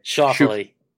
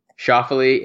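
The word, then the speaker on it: Schauffele: Shoffley. – Shoffley, 80-1.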